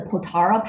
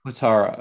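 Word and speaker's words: Potala: And so how do you say the name of the palace again? – The Potara Palace. – Potara. Okay.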